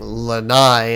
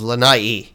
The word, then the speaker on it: Lanai: I talk to him a lot, so I've been getting into the habit of saying Lanai instead of Lanai, which is the proper pronunciation of that beautiful island.